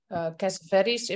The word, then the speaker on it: Cassavetes: I think a big inspiration for me is Cassavetes, if I pronounce it correctly. – Yeah, that's right. Yeah.